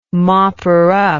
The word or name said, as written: mopper-up